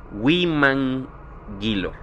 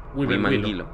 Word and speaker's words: Huimanguilo: Soy estudiante de enfermería y estaba dando mi práctica en una clínica aquí en el municipio de Huamanguilo. Perdón, pero es que no sé pronunciar bien. se escribe, es Huimanguilo. – Huimanguilo. – Ándale.